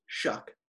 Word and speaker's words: Shough: Mr. Shuck, Tyler Shuck, the quarterback for Oregon, whose last name is spelled S-H-O-U-G-H, Shuck.